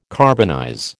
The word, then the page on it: carbonize: American English